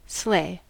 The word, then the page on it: sleigh: American English